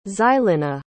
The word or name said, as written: xylina